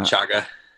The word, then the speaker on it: chaga: Yeah, I've used, I don't know if you pronounce it chaga or shaga. – Yeah, chaga.